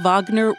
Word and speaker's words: Wagner: For people who might not know, what is the Wagner Group or the Wagner Group? – So I pronounce it Wagner because the neo-Nazi who founded it was inspired by the composer Wagner's professed Nazi sympathy.